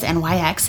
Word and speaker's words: NYX: You may have seen it as NYX in the stores. – That's how you pronounce that? – Yes. – I've been saying NYX to myself in my mind the whole time.